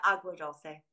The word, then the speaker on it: Agua Dulce: Is it Agua Dulce? – Yeah, Agua Dulce.